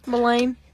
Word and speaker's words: Millane: How would y'all say that? – Millen. – Millen? Okay. – Millane. – Millane? Millane. Millane. Maybe.